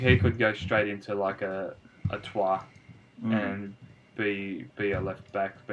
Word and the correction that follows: Troyes: No disrespect to whoever they have at left back at the moment, but I believe it's pronounced Troyes.